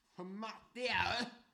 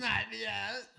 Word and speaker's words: Hamartia: Hamartia. – Hamartia. – One more time, please. – Hamartia. – That's exactly how I think you're supposed to pronounce it.